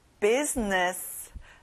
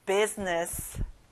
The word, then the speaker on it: business: 사업 또는 직장의 일, business. 가운데 I 발음은 안 나요. business.